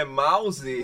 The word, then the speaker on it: MUOS: Teve alguma pessoa que perguntou, ei, como é que fala isso? É mouse? – Mouse. – mouse, é. é.